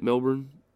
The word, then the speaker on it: Melbourne: Isn't that how you say it, Melbourne? – Yes.